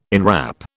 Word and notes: enwrap: British English

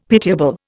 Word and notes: pitiable: Indian

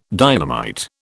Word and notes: dynamite: British English

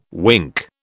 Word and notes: wink: British English

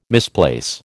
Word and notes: misplace: American English

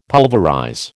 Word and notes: pulverize: American English

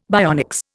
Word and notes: bionics: Indian

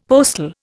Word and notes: postal: Indian

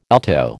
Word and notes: alto: American English

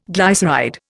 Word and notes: glyceride: Indian

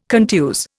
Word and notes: contuse: Indian

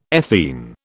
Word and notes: ethene: British English

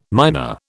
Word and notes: minor: British English

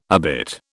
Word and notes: obit: British English

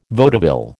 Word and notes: vaudeville: American English

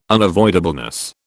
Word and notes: unavoidableness: British English

